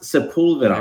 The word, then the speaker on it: Sepulveda: It is pronounced Sepulveda.